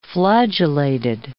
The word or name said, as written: flagellated